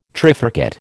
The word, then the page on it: trifurcate: American English